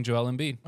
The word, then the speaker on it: Joel Embiid: I'm choosing Joel Embiid.